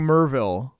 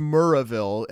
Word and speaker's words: Maryville: Escaping Merville. It's like you hang on that R a little bit. It's almost Murrahville, M-U-R-R-A-H-ville. But you slur the two of them together, Murrahville.